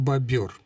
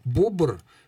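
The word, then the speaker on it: бобёр: Основной является форма бобер, но бобр... Я сам в свое время с удивлением это обнаружил. Бобр тоже допустимая форма.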